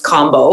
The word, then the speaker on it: Kambo: I've heard so many different things, and I know, no, it's pronounced combo, like almost like an O, not A.